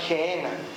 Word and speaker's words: Gehenna: Infierno como tal, Jesús la pronunciaba Gehenna.